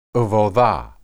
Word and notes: ebudah: hebrew

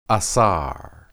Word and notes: asar: Hebrew

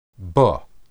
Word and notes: be: Hebrew

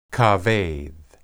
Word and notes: kabed: Hebrew

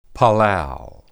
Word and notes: palal: hebrew

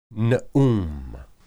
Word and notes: neum: Hebrew

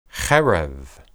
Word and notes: hereb: Hebrew

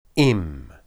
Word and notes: im: Hebrew

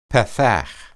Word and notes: pathah: hebrew

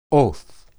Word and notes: oth: hebrew

